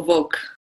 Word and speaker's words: Volk: Did I say your name right? Is it Volk or Volk? – Volk. You Volk. Did it very well first time.